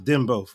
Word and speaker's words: Dembow: I know Shaba Ranks is the one who had a song, you know, that was called I think it's pronounced Dembo, for what I know. D-E-M-B-O-W.